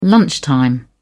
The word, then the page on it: lunchtime: British English